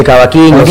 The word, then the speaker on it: cavaquinho: Mira, yo viendo tu resumen curricular, experiencia de vida, tú eres ejecutante del cuatro venezolano, de la guitarra, del bajo eléctrico, del cavaquinho, ¿se pronuncia así? – El cavaquinho, sí.